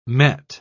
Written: met

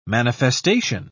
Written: manifestation

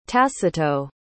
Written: tacito

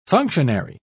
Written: functionary

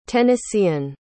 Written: tennessean

